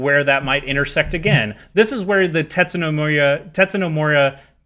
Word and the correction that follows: Tetsuya Nomura: I mispronounced his name twice, Tetsuya Nomura, just came off of Kingdom Hearts 3 to jump in and work on FF7 Remake.